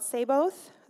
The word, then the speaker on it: Sabaoth: And he is Jehovah, I think it's called Sabaoth. Is that how you pronounce it? Yes.